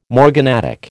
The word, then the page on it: morganatic: American English